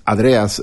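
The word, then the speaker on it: Hadreas: Este apellido un poco difícil de pronunciar, en realidad es Hadrias, o sea, de ascendencia griega.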